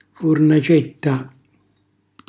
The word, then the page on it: furnacetta: Calabrese